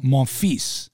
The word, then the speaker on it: Monfils: If you don't know how to pronounce Gaël Monfils, and you pronounce it Monfil or whatever. – Monfi. Without the S.